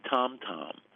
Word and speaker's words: Tam Tam: Some listeners will remember or even know there's a cracker called T-A-M-T-A-M, and we always called it Tam Tam, but it's actually Tom Tom, and Tom is umami.